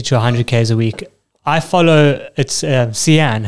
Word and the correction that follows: Sian: hey, is that how I pronounce her name? Sian. Obviously, Sian. – Hey, Sean. – Pardon? – Sean. – Sean, Alden. Yes.